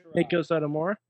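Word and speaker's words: Meiko Satomura: Miko Satomura? – Yes.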